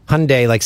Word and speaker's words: Hyundai: Hyundai, like Sunday.